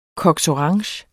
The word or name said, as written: cox